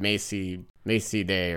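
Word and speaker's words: Mazey Day: Macy Day or something. It rhymes with Macy Gray.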